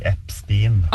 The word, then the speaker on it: Epstein: Jeg sier Epstein, og det vist det vi skal si, men det jeg la merke til var jo at du hørte cirka 5-6-7 ulike uttaler av det, vi som satt der, så der er det mange som sier feil, men du skal si Epstein.